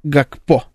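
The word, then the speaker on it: Gakpo: Надо так немножечко. Гакпо. – Ну, да, то есть... специально, чтобы... – Да, нет, это надо выводить, да. Ну, мне тяжело тоже, почему произносится. Гласное посередине, это. Ну, вообще странно.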